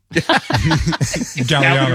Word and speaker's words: Galliano: Or is it Galliano? How do you say that? – I had that in my left foot. Idea. – Galliano. You're right. Galliano, and you're right.